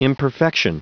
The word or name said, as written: imperfection